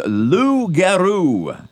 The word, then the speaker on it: loup-garou: It's a loup-garou. – I joined in again. – There you go. – My goodness. Audience participation here from the host. And that is loup-garou. And it's spelled L-O-U-P-G-A-R-O-U. Loup-garou.